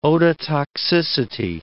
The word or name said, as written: ototoxicity